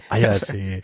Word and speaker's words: Hayate: Hayate, das H ist stumm. – Ja, mein, okay, Mann. Ayate.